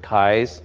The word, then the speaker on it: Keyes: He pronounced it Keyes, looks like Keys, and the name of his firm is Prairie Land Surveying.